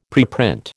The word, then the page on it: preprint: American English